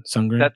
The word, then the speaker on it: Sundgren: Sundgren? – The D is silent.